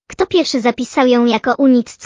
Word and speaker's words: Unix: Co więcej, wymawiane po angielsku Unix brzmiało identycznie jak Eunuchs, czyli Eunuchowie, co dodawało nazwie dodatkowy, nieco pikantny humor.